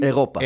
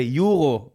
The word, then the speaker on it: אירו: זה uh, תמיד ההתחלה של איך אומרים אירופה. אירופה בשפה שלך. הגיוני. אז זה יורו באנגלית. ואירו בעברית.